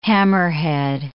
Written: hammerhead

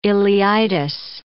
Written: ileitis

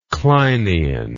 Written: kleinian

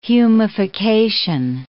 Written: humification

